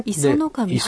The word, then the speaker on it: 石上: 磯の神磯の神、はい、これね、石神と書いて磯の神と読むんですね。